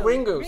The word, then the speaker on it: Ringu: I can't read that. Runji? – Ringu? – Oh, Ringu. Sorry, Ringu, on which the cult classic movie was based.